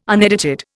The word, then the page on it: unedited: Indian